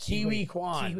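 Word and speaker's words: Ke Huy Quan: Kiwi. It's actually pronounced Kiwi Kwan. – Kiwi Kwan, yeah.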